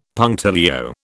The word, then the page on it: punctilio: British English